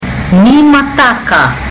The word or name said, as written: nimataka